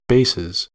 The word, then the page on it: bases: American English